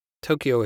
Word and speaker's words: Tokyo: Tokyo is in English spelt without a U, but actually is pronounced with a U after the O. Each O has a U following it.